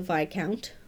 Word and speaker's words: Viscount: So the romance novel that this movie made me think of is, The Viscount Who Loved Me, or actually I think it's properly pronounced The Viscount Who Loved Me by Julia Quinn,